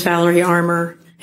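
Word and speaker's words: Valerie Armor: My name's Valerie Armour. It's spelled V-A-L-E-R-I-E. Armour spelled A-R-M-O-R.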